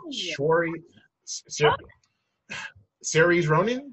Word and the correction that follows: Saoirse: Uh, yeah, Saoirse.